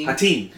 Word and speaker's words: Hattin: Yes, the Arabs, Muslims, starting with Saladin, essentially at the Battle of, how do you pronounce it, Hattin? – Hattin, the Horns of Hattin, yes.